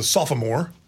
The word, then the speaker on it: sophomore: Who's a sophomore. Or as Bob Davis always says, a sophomore. He said there's three O's in that word. You pronounce them all.